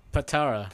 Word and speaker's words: Patara: Does that really say in Pantera? P-A-N-T-A-R-A. Fuck y'all, I'm dyslexic. I can't read this. – It says Pantera? – I feel like that's pronounced Pantera. – What does it say that it's... – Not Panthera? – Panatera. – Patara. – Patara.